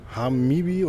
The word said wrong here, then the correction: Hibana: oder Hanibi. – Hibana.